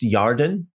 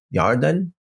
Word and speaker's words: Yarden: Again, this would be Shittim, Shittim, and came to the Jordan, or more accurately pronounced, Yarden, Yarden.